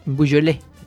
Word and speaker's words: Beaujolais: La etapa 12 entre Rohan y Belleville en Beaujolais, sí.